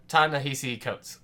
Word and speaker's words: Ta-Nehisi Coates: Number 4, The Water Dancer by Ta-Nehisi Coates. Did I say that right? – Yeah. – Okay.